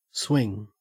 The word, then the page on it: swing: Australian English